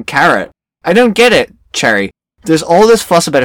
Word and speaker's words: Cheery: And it's like, we have, is it in this book where they say, like, Cherry's name is C-H-E-E-R-Y, but it's pronounced Cherry.